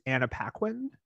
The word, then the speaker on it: Anna Paquin: For Vivian, he picked Anna Paquin.